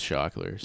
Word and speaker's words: Shockler: Anyways, so I spelled my artist name with a C, so people pronounce it right because it's Shockler.